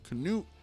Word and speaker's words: Canute: I think it's pronounced Canute, he had promised Magnus his kingdoms after his death.